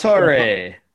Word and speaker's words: Paratore: Paratore.